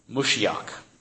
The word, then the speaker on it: Mashiach: The Hebrew equivalent is, I looked up the pronunciation, so hopefully this is correct, Mashiach, M-A-S-H-I-A-C-H, or Messiah.